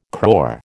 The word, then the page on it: crore: American English